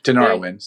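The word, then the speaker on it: Tinariwen: So there's a chance for Tarawin to win that. Tenarwin, sorry, pronounced that wrong.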